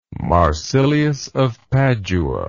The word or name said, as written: marsilius of padua